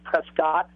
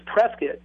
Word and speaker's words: Prescott: On the map, it looks like it's pronounced Prescott, but it's Prescott.